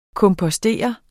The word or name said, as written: kompostere